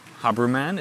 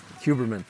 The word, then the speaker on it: Huberman: And now I switch to English because uh, my guest is Professor Dr. Andrew Haberman. Is that Huberman.